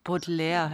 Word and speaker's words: Baudelaire: Charles Baudelaire, habe ich es richtig gesagt? – Baudelaire heißt das.